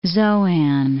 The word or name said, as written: Zoan